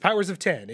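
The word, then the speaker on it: Powers of X: It's Powers of Ten. It's written with an X, but it's they have said it's pronounced Ten.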